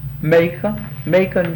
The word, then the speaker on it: Macon: How would you say that? – Macon, Georgia. – That's right.